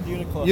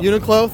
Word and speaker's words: Uniqlo: Uniqlo, whatever. I'm wearing my coat. – It's called Uniqlo. – Uniqlo, thank you.